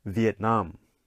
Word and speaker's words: Vietnam: All right, let's go to our next country, Vietnam. Okay, Vietnam. Okay, so here you can see the nam gets the stress, Vietnam.